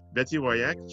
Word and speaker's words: Woyach: Betsy Woyach, she is with Momentum Dance Company of Santa Barbara.